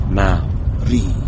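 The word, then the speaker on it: Māori: Say Mao like as in Chairman Mao. – Maori.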